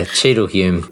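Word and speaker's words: Cheadle Hulme: But I think it's Cheadleholm. I've got a feeling we've been told that before. – Yeah, Cheadleholm. – That's a silent L.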